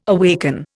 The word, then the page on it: awaken: Indian